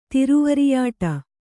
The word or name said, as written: ತಿರುವರಿಯಾಟ